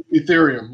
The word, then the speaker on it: Ethereum: Meaning, like Bitcoin is worth this amount, and then there's Ethereum. Am I saying that right? – Ethereum, yes.